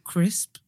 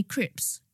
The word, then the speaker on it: crisp: Or I say crisp. Apparently, I can't say that right. – Oh, you said it fine just then. But some people used to say crips.